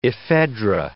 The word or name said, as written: ephedra